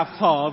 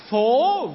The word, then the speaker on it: phố: Có bạn đọc là phở Việt Nam, nhưng mà ý tác giả ở đây đó là phố Việt Nam, đường phố Việt Nam.